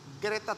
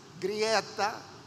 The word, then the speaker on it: Greta: Ahí tiene, hermanos, esta niña sueca, que nosotros eh, el nombre lo leemos como Greta Thunberg, así lo leeríamos en español, pero como es un nombre sueco, la auténtica o la correcta pronunciación es Grieta Thunberg.